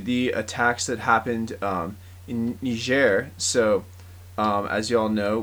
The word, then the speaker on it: Niger: but no, it is Niger.